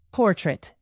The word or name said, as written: portrait